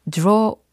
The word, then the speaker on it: drawer: 서랍은 drawer war이라는 발음인데 이것을 제가 가끔 암기를 도와드린다고 서랍을 열고 어우 드러워라고 이렇게 강의를 할 때가 종종 있었거든요.